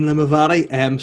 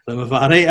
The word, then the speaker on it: Limavady: Yeah, it shouldn't be pronounced Limavady, I don't think. – what way would you pronounce it? No. – Oh, yeah, no. Just Limavady, I think, yeah.